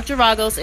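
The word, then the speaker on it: Geragos: So, Kaepernick's attorney, Mark, oh God, I'm going to butcher his last name, Giragos? Oh no, I think I said it right. Mark Giragos and Ben, oh, that's a tough one.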